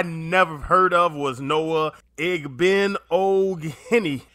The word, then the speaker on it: Igbinoghene: He tweeted, for those wondering, your new Dolphins cornerback is pronounced Noah Igbinoghene.